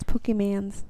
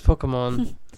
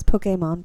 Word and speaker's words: Pokemon: I think you're saying it wrong. It's Pokemans. – No, it's Pokemon. – It's Pokemon, but okay.